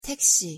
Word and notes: 택시: Korean

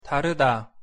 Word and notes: 다르다: Korean

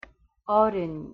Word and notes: 어른: Korean

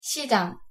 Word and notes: 시장: Korean

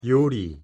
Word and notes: 요리: Korean

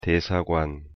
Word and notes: 대사관: Korean

